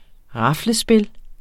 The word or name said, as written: raflespil